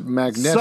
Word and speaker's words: Magneto: His name is pronounced Magneto.